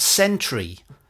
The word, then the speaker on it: Centree: This week, the Green Edge talked to a new company called Sentry, that's spelled C-E-N-T-R-E-E, which is part of this new blockchain-enabled ecosystem.